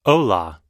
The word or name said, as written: hola